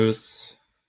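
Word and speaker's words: Meeus: Äh, Jordi Mois. – Meus. Mös. Also Mös. er wurde Mös ausgesprochen von, von Bernie Eisel.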